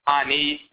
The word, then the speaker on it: Ani: Then you add the E at the end of it, or the Y, which is pronounced E. So Ani, meaning the stable one.